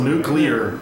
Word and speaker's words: nuclear: And everyone yells nuclear. It's like the word clear.